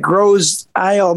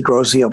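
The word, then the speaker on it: Grosse Ile: He did his little chuckle and said, who knew that Gros Isle, Michigan was the capital of rock and roll? And it's actually Gros Isle, but I don't want to tell him. He pronounced it wrong, but that was pretty funny.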